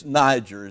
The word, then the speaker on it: Niger: It's Niger is the way you pronounce it. It's the Latin word for black.